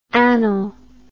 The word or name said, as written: annal